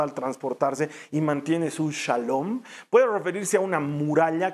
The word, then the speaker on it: shalom: De hecho, en hebreo, la palabra hebrea que se pronuncia como paz en español, en hebreo se pronuncia como shalom, shalom.